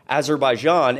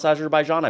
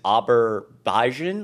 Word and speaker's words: Azerbaijan: We also saw recently him not even being able to pronounce Azerbaijan. I believe that was in the last video I made on this channel, where he says Aberbaijan, like he doesn't, he's, you know, getting the letters mixed up.